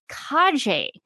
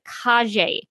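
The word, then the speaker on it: Calle: and I believe her name is pronounced Kaje. I looked up a video. I looked up a video of her, and this will be in the video description below. And I listened to her say her own name, and to me, it sounded like Kaje.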